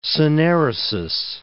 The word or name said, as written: syneresis